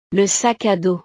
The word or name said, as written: le sac a dos